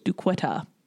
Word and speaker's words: Duquette: Denny Duquette. – And Denny I believe it's pronounced Duquet. – It's pronounced Duquetta.